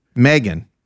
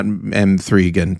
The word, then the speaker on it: M3GAN: Actually, in this last one, Megan, which I assume it's pronounced Megan, not M3gan, but Megan is amazing.